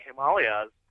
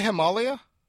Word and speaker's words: Himalaya: You say Himalaya? – Himalaya, yeah. A lot of Americans say Himalaya.